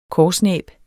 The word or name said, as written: korsnæb